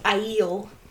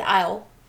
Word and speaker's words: Aiel: And because I'm the pronunciation nerd here, I have to tell you, it's actually pronounced Aiel. – Is that what I said? – You said aisle. – Aisle? Like aisle of the like supermarket? – Groceries. Yeah, like grocery store aisle. Aisle. Aiel.